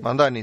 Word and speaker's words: Mamdani: el alcalde Mamdani. No sé si lo pronuncié bien. – Mamdani, sí.